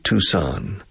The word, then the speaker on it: Tucson: Por cierto, Tucson, se pronuncia aquí Tucson. The pronunciation in English is Tucson.